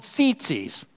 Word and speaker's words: tzitzit: It's spelled T-Z-I-T-Z-I-T. Zit. Come on, say tzitzi with me. Come on, you're a teenager. You had the zits. Come on. Tzitzi. Tzitzis, okay?